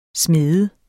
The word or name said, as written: smed